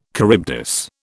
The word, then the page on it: Charybdis: British English